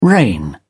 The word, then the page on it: reign: British English